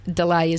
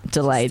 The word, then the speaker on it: DLA: So it's DLA.AUS, but Delay is the name. – Delay.